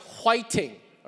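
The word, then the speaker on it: whiting (화이팅): Now, in Korean, it's pronounced whiting, all right? But it really is the same word, right? Fighting, whiting. But it's the idea of cheering somebody on.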